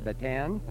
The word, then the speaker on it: Bataan: If I remember from our interview, after you went through that horrible ordeal of the Bataan March, am I saying it right, Bataan? – Either way.